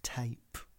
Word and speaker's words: TAIP: It's spelt T-A-I-P, Tape, because it uses AI.